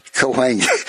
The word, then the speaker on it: Cahuenga: Coalinga? – Cahuenga. Oh. We both butchered it. – No, that? But how's it spelled? – C-A-H-U-N-G-A.